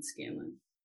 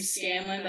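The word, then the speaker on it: Scanlan: Or you can find me on Facebook, Pauline Scanlon. – How do they spell it? – My first name is P-A-U-L-E-N-E, not I. Last name Scanlon. That's S-C-A-N-L-A-N.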